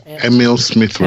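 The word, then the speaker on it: Emile Smith Rowe: Yeah, Emil Smith Rowe.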